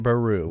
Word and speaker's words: Beru: Aunt Peru? – Aunt Beru with Beru. A B.